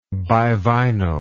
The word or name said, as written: bivinyl